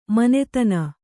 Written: ಮಾನಧನ

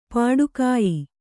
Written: ಪೊಟ್ಟುಗೆಯ್

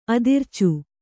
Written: ಅದಿರ್ಚು

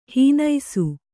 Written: ಹಣ್ಣಿಸು

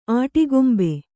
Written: ಅಟ್ಟಗೊಂಬು

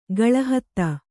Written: ಕಲ್ಹತ್ತಿ

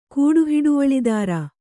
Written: ಕೂಡುಹಿಡುವಳಿದಾರ

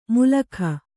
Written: ಮುಲಖ

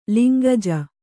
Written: ಲಿಂಗಜ

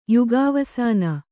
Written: ಯುಗಾವಸಾನ

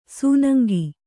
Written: ಸೂನಂಗಿ

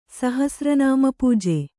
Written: ಸಹಸ್ರನಾಮಪೂಜೆ

